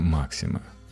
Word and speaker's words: Максим: Максима – кстати, мы традиционно произносим название его пулемета «Максим» с ударением на втором слоге, но фамилия оружейника произносится на самом деле иначе, с ударением на первую «а».